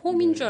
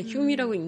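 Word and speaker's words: Home: 홈인 줄 알았는데 여지껏도 홈인 줄 알았는데 흉이라고 읽는다고 합니다.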